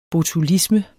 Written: botulisme

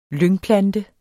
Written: lyngplante

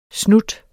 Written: snut

